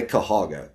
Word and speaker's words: Cuyahoga: That whole middle part, we just leave off. And we all say Cuyahoga, Cuyahoga Falls, Cuyahoga River.